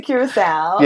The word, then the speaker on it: Curaçao: Is that how you say it? – We went to Curaçao. – Yes.